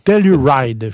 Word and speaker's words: Telluride: Telluride, je vous dirais. T-E-L-L-U-R-I-D-E, 2020.